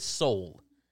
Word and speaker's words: Seoul: By the way, Ben and McKinnon both corrected me. It is Seoul. Like, that's how it's pronounced.